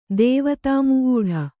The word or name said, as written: ದೇವತಾಮುೂಢ